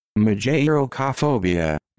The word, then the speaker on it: Mageirocophobia: Mageirocophobia.